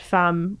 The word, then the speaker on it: femme: I mean, there's a saying in French that femme, which is the word for woman and also wife, rhymes with infâme, which is infamy.